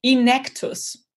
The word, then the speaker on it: Enactus: Wow, also erstmal habe ich es dann ja anscheinend falsch ausgesprochen. Ich habe ja Enactus gesagt, dann heißt es Enactus. Genau, also je dem, wie man es nimmt, hier in Deutschland sagen viele auch Enactus, aber dadurch, dass die Initiative ursprünglich aus Amerika kommt, wäre Enactus eigentlich richtig.